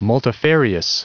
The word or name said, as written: multifarious